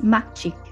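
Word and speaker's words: Makcik: It's pronounced Macik, Malay for auntie.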